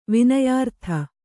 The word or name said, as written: ವಿನಯಾರ್ಥ